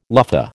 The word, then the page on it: luffa: American English